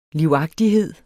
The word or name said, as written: livagtighed